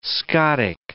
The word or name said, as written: Scotic